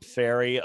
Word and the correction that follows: Farley: of uh, Virginia Tech, uh, Farley is I think how you pronounce it actually.